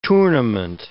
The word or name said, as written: tournament